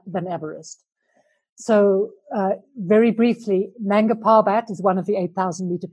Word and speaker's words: Nanga Parbat: Is it Nanga Parbat? – Nanga Parbat.